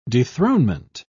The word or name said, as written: dethronement